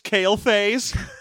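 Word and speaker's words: Quel'Thalas: And, well, that's pronounced Kel'Thalas. They never say it. – Remember the sun well. I'm Kel'Thalas. – It's pronounced Kale Face.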